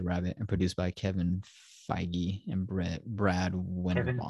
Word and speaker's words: Feige: It's spelled phage, but whatever. It's spelled like beige would be spelled, so therefore it's pronounced phage.